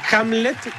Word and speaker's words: Hamlet: Ahora, Hamlet, porque no lo dije mal. – ¿Entiendes? No, perfecto, lo dijiste bien.